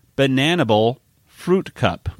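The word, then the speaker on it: Bananibal Phruwtkup: Ah, In other words, it's pronounced bananable fruit cup.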